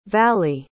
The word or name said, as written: vali